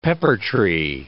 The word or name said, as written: peppertree